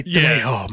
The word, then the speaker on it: Trejo: How would you say that? Trejo. – Trejo. It's Danny Trejo, my friend.